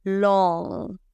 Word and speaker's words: long: Y que suene la G. Vamos a, escuchar esa G de rey. Long. Exacto. un poquito. No es que digas long y te atragantas, pero ha de sonar un poquito.